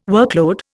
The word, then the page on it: workload: Indian